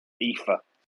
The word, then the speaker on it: Aoife: How do you say her first name? – UK in about 8 months. Aoife. – It's not spelled that way. – I know. It's Irish spelling.